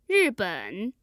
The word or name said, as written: riben